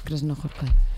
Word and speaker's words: Krasznahorkai: Krasznahorkai. – Krasznahorkai.